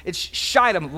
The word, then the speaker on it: Shittim: It's Shittim, long I, from Shittim.